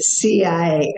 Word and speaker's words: Sia: I was the CIA, you know, and I'm thinking in Egyptian, CIA but they spelled it SIA, but pronounced it CIA.